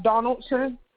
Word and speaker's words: Donaldson: T-R-A, V as in Victor, I-S-H-A. – And may I have your last name, please? – Donaldson, D-O-N-A-L-D-S-O-N.